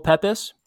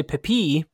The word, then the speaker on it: Pepys: I think that's what we've agreed on for pronunciation. – Yeah, I think so. – Samuel Pepys. I kind of want to say Pepi, but he's English, so we can't do any fun French stuff.